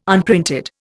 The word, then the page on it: unprinted: Indian